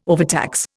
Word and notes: overtax: Indian

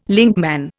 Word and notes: linkman: Indian

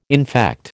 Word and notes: infect: American English

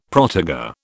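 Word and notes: protégé: British English

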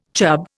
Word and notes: chub: Indian

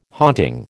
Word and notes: haunting: American English

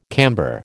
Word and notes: camber: American English

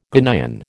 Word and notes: Ghanaian: American English

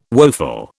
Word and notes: woeful: British English

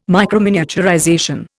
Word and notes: microminiaturization: Indian

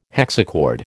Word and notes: hexachord: American English

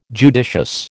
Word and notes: judicious: American English

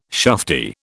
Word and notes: shufti: British English

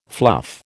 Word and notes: fluff: American English

